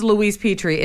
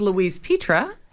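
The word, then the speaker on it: Louise Pitre: For all of you who say it's Louise Petrie, it's not. It's Louise Petra.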